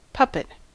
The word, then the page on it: puppet: American English